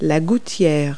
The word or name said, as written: gutter